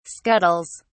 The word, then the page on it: scuttles: British English